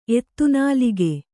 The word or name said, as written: ಎತ್ತುನಾಲಗೆ